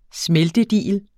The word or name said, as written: smeltedigel